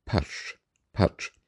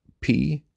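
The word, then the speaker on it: Pötzsch: It's a rather difficult name to pronounce and read. Oliver is easy enough, but when it's Potzsch, Putch, and the O has an umlaut, this makes the pronunciation very difficult, since there are five consonants in a row.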